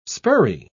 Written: spurrey

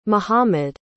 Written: mohammad

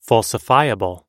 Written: falsifiable

